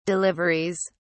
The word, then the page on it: deliveries: British English